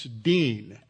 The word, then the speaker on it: Deen: It's like my last name. It's Deen. That's how it's pronounced.